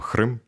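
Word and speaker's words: Qırım: Насправді, альбом Хрим, я правильно вимовляю? Так, супер, ідеально.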